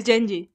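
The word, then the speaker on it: Jenji: Y está entrevistando a Kenji, eh, a, la creadora Kenji Kohan, o como se pronuncie, y, y curiosamente le estaba diciendo oh, Kenji, eh, Genji, o... es Genji. Genji, perdón, Genji Kohan, eh, que...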